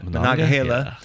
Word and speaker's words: Monongahela: So in the Battle of the Monongahela, whew, how do you say that? – Monongahela.